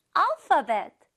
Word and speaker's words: alphabet: Well, you can say Korean alphabet. 네, 우리 친구들 알파벳이라고 우리 많이 쓰죠? 그 알파벳이 맞아요. 발음 체크할게요. 알파벳. 알파 아니에요. 파. F 발음이 나고 있죠?